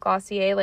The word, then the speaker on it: Glossier: It's like Glossier, but they pronounce it Glossier, like it's French.